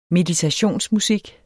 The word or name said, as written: meditationsmusik